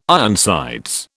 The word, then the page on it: Ironsides: British English